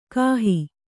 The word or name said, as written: ಕಾಹಿ